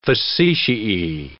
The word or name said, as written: facetiae